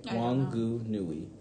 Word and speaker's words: Wanganui: Wanganui.